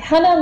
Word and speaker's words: Hana: And the correct pronunciation is Hana. So you were close. – Hana with sort of a soft A. – Exactly, exactly. Hana like honey. – Ooh. – Sweet like honey.